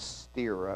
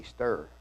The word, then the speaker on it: stir: Every time I see the word stir up, I think of when I used to pronounce it as steer up, until one of the brethren in the church said, I think it should be stir.